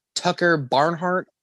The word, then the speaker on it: Tucker Barnhart: Okay, got to say congrats to the Reds catcher Tucker Barnhart on his second goal. Attaboy. I thought you were going to butcher the fuck out of that, but you got it.